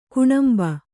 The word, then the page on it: ಗೇಣಂಬು: kannada